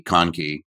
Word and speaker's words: Conkey: Hey guys, it's Toby Mathis, and I am joined today by Jen and Stacy Conkey.